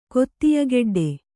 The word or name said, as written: ಕೊತ್ತಿಯಗೆಡ್ಡೆ